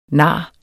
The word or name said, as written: nar